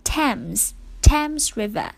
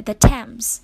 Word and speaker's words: Thames: 对了，这个泰晤士河它的标准的发音呢是 Thames River， OK，或者 The Thames 就是泰晤士河的意思。